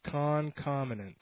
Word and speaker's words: concomitant: Concomitant. Yeah, concomitant. It's accompanying, attendant, occurring, or existing concurrently. – I guess it makes sense. – Concomitant. Yes, I believe that is. It's K-U-H-N-K-O-M-U-N-T-U-H-N-T.